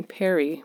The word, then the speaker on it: Pari: So my daughter calls me Pari, which is spelled P-A-R-I, and it's short for parent.